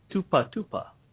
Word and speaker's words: Tupa Tupa: The title of the song is Tupa Tupa, if I pronounce it correctly. – Yes, Tupa, yeah.